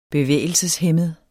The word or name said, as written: bevægelseshæmmet